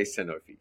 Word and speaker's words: Sanofi: I say Sanofi, so that's all. – 5 points. – No one tell me I say it wrong then.